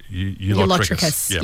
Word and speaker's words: ulotrichous: ULOTRICHOUS. Or you lot trick us. You lot trick us. – Right. – But it's pronounced ULOTRICHOUS.